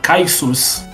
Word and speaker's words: Kyuss: Chamada Kaisus, que se pronuncia com K-Y-U-S-S.